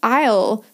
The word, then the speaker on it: aisle: So if you've never heard the word aisle before, it's spelt funny, A-I-S-L-E. I'm not going to spell all the words, but that's a funny one that is written a bit differently to how you would say it.